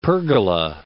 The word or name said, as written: pergola